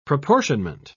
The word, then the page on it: proportionment: British English